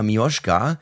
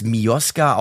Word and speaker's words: Miosga: Und laut Wikipedia ist die in Anführungszeichen offizielle Aussprache Mioschka, es steht da aber meistens als Mioska ausgesprochen.